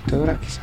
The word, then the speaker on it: Theodorakis: Ay, bueno, ese disco que hizo tu compatriota Theodorakis, o ¿cómo se pronuncia? – Theodorakis. – Ay, uh-huh. Yo pensaba que era Theodorakis, pero bueno.